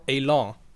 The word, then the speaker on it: élan: You can use it in English. – Élan. – Élan. Élan. Yeah. Yeah. We don't pronounce it right.